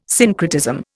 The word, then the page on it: syncretism: Indian